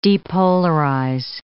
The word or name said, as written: depolarize